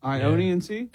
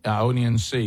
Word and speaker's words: Ionian: How do you say it? I-O-N-I-A-N-C? – Ionian Sea? – Ionian Sea. Yeah, I went through.